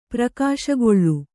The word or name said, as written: ಪ್ರಕಾಶಗೊಳ್ಳು